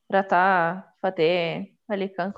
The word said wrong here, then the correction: Rahat: Como é que fala o nome dele, gente? – Rahat Fateh Ali Khan. – Ratha, eu sempre falei Ratha. Não era Ratha Era Rahat.